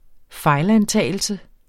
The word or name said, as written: fejlantagelse